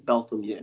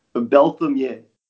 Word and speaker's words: Beltamier: Technically, like, my name is pronounced Stephen Beltamier. It's very French. I've been listening to people butcher my name literally since day one. – Beltamier? – Beltamier.